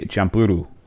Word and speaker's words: Champloo: So, according to Wikipedia, it's pronounced Samurai Champloo.